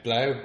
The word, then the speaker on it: Blaauw: How do you say B-L-A-A-U-W? – Blau. There's probably some strange South African pronunciation that we miss.